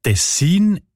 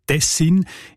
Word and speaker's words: Tessin: Die Variante mit der Betonung auf der ersten Silbe und mit kurzem I, also Tessin, ist seltener.